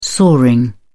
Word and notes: soaring: English; British English

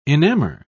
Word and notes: enamour: British English

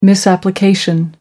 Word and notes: misapplication: American English

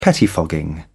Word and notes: pettifogging: British English

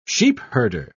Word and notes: sheepherder: British English

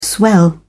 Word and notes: swell: British English

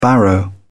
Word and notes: barrow: British English

